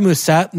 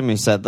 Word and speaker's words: Mousset: I'd rather have Mousset, not close, for a million less. – Mousset, I don't think, dude, that's, don't say that. Don't pronounce that. The T is French, dude.